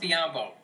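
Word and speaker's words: Biyombo: They lost Biombo. – Yeah. – Rhymes with Matumbo, not a big deal still.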